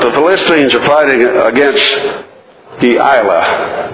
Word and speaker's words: Delilah: But Delilah, three syllables, the middle syllable having the accent on it.